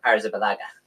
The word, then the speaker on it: Arrizabalaga: Arrizabalaga. – Arrizabalaga, you said, is the best 5.5 million set and forget.